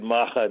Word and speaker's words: Maja: So the name of the book is Goya's Ring and the Naked Maja Dancing. It's M-A-J-A. It's pronounced Maja.